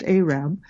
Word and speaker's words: Arab: It's spelled Arab, but it's pronounced Arab.